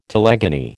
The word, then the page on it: telegony: American English